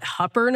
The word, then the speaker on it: Hooper: And sure enough, they call it Hupper, Nebraska, but it's spelled Hooper.